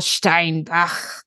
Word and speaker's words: Steinbach: Your pronunciation is very reflective of the fact you lived abroad for a little while. – Yes, we were just discussing. – Because I am an American, as everyone can hear, but I did marry into a German family when I lived there for a long time. So it's that delightful Steinbach.